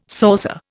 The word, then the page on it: saucer: Indian